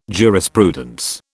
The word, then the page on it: jurisprudence: British English